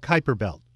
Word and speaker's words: Kuiper Belt: I have a question about the asteroids and Kuiper Belt objects, if I pronounce that right. Is that right? – Kuiper Belt, yes.